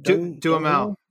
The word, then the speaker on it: Duhamel: How do you say his name? Dunham? – Duhamel. Josh Duhamel.